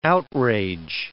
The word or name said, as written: outrage